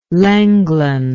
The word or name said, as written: Lenglen